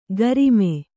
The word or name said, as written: ಗರಿಮೆ